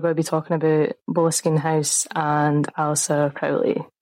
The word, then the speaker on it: Boleskine: However, there have been many ways I have seen Boleskine pronounced. Some people say Boleskine, some people say Boleskine, but me and the Gaelic Umphies have discussed, and we have decided that it would be pronounced like from the Gaelic Boleskine, which is like the area name. So Boleskine is like the closest I can get to that.